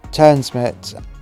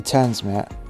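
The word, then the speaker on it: TRNSMT: In July 2019, they performed again at Turnsmut, this time the main stage. When I say Turnsmut, I'm probably pronouncing it wrong, but it's T-R-N-S-M-T.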